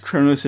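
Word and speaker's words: Qo'noS: Oh, incidentally, the apostrophe in Kronos is after the first O. And it's pronounced Kronos in English because it's transliterated. It's spelled Okay. – QO apostrophe NOS.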